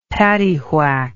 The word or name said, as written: paddywhack